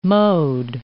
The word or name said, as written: mode